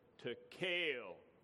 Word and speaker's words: Tekel: The first syllable has like a short E sound, and then the second syllable has like a long A sound. And so it's Mane, Tekel, Peres.